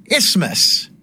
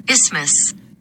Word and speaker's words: isthmus: Well, now, years later, I'm back to rock the airwaves of the Mad City, and I have to brush up on my pronunciation of the word isthmus. Right? Is that right, Siri? – Isthmus. – Wait, one more time. – Isthmus. – Isthmus. There we go.